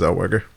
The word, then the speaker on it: Zellweger: And he's like, who the hell is Renee Zellweger? But it's pronounced Zellweger.